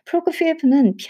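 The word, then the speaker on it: Prokofiev: 피에프라고 이렇게 F로 발음이 나는데, 프로코 피에프는 피아노 협주곡 2번이 더 어려워.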